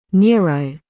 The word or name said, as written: neuro-